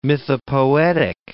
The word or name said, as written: mythopoetic